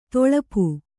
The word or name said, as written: ತೊಳಪು